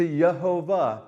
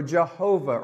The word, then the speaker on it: Jehovah: You commit your way of thinking, of speaking, of living to Yehovah, where you see the word Lord capitalized, all the letters, L-O-R-D caps. That speaks of Yehovah or Jehovah or Yahweh, different pronunciations of that same name, yes?